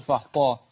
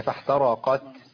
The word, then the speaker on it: فاحترقت: وكذلك في قول فاحترقت بيكون بالطاء لا ينطقها بالآ بالتاء. – فاحترقت. – فيقول فاحطاء. – ما أعرفش يعني إيه احترقت. – هنا. – يا فاحترقت. لابد من ايه من تدقيق التاء مع الهمس.